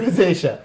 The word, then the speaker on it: rosacea: Rosacea. – That one. Yes. Right. Rosacea.